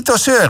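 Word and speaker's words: Tozeur: Il treni di Tosseur, hè? Zo zeg ik het goed, toch? – Ja. Heel goed.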